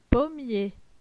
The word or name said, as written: pommier